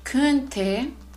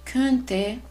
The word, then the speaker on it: könnte: Ich könnte, y ojo aquí con la pronunciación. Ich könnte, du könntest, er sie es könnte, wir könnten, ihr könntet, sie und sie könnten.